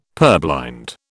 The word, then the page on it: purblind: British English